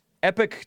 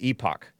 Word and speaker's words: Epoch: It's pronounced Epoch Times, it's spelled like Epoch Times. And if you're in the UK, I think you pronounce it Epoch. That's how I'm tempted to pronounce it, because it's Epoch Times.